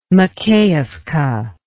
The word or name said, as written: Makeyevka